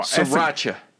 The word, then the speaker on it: Sriracha: It's spelled S-R-I-R. Sriracha. – A-C-H-A.